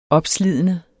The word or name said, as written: opslidende